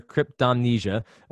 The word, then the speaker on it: cryptomnesia: And Mike, the correct pronunciation as you gave there, cryptomnesia,